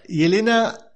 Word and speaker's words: Yelena: Y Elena Velova. – I know Velova, pero, es con velario... se pronuncia. Es medio raro el Velova. – Bien. – Pero es Yelena.